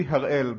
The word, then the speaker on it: Harel: It should be Harel, but I can forgive you for saying Harel.